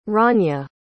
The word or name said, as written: ranya